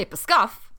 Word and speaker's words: Pskov: It's P-S-K-O-V, so you gotta say Pskov. – You gotta say Pskov.